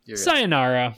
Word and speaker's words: sayonara: Mark Ingram is someone I am more than happy to say sayonara to. I'm not even saying that right. Oh, FML. – Sayonara. – Sayonara.